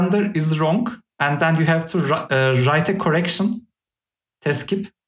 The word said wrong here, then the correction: tekzip: is the Turkish word, you put it to the fifth page on the bottom. – Isn't it tekzip? – Tekzip, yes, I said it wrong.